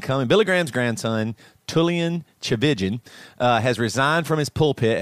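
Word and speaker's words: Tchividjian: It's pronounced Chavijan. Yeah. – Chavijan is how the phonetics of it are.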